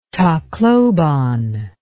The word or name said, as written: tacloban